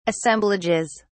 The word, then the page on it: assemblages: British English